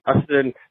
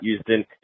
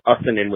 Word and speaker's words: Usdin: Hey, Scott and Brian, this is Rob Ustin. Yeah, you guys always mispronounce my name. I know it's spelled U-S-D-I-N, but it's actually Ustin, not Ustin. It's Ustin with a D in the middle.